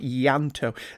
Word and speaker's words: Ianto: The I has a soft E sound, and all put together, it's close to sounding like Ianto.